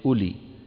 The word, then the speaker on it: أولي: هذه الواو لا تلفظ. تبهنا هكذا كانت تكتب العرب كلمة أولي. تبهنا لعله حتى تفترق عن إلى التي هي حرف الجر فكانوا يزيدون فيها هذه الواو تكتب ولا تلفظ. أولي تبهنا ما أحد يقول لي أولي. الواو هذه لا تنطق وعلامة أنها لا تنطق وضع السكون المدور فوقها.